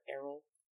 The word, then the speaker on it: Errol: That's Errol, right? – Yeah, that's how you pronounce it. Errol.